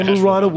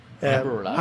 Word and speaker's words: Amburana: Vanilla and Aberanda. No, excuse me. I pronounced that wrong. Sorry. – Ashwagandha. – Excuse me. Amburana wood.